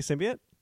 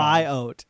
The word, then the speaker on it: symbiote: So I've only ever heard it pronounced symbiote. – I've heard symbiote. – That's not totally wrong. – It's not totally wrong? – Yeah, but you don't usually hit the O that hard is all.